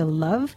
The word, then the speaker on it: Luv: or Facebook at DJ Cherish the Love. That's spelled L-U-V, and hashtag using Primary Food, Heritage underscore radio, and hashtag RevLove.